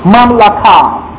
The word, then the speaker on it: mamlakah: The word kingdom is from that Hebrew word mamlaka. Mamlaka. I'll spell it. M-A-M-L-A-K-A-H. Mamlaka. And from another word, Malkut. M-A-L-K-U-T-H.